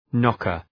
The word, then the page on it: knocker: ‘nɒkər